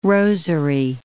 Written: rosary

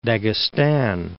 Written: Daghestan

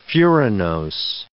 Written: furanose

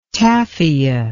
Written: tafia

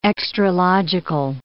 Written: extralogical

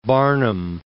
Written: Barnum

